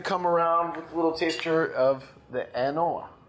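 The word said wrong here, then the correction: An Oa: Is that how I pronounce it? – Terrible. Anno.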